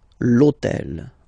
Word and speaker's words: hôtel: And third example, so I took on purpose this hôtel, okay, because it starts with H, but remember that in French we don't pronounce H, okay, so basically the first sound of the word is the vowel O, okay, so for the same reason, E needs to disappear, l'hôtel, okay, so if we repeat them, le chien, l'ordinateur, and then l'hôtel.